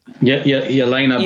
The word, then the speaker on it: Yelena: But anyway, my whole point is that Yelena, is that how you say it? – Yelena Belanova.